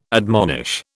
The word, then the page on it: admonish: British English